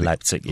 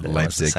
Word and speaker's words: Leipzig: Leipzig, yes. – Leipzig, thank you.